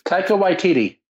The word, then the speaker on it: Taika Waititi: All right, it's like Takiti. I can never say his name. Anybody's? – Taika Waititi.